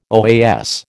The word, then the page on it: OAS: American English